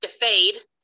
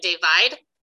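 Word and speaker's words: dafad: It's actually a Welsh word, and it's not pronounced defade in Welsh. It's pronounced more like divide, but we knew nobody would look at it and pronounce that correctly, and it means sheep in Welsh.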